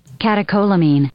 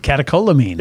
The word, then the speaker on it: catecholamine: Okay, so the term for this week, I'm going to see if I pronounced it right. Catecholamine. Okay, let's get one of these pronounced things right. – Catecholamine. – Ah, not bad. Good. Catecholamine.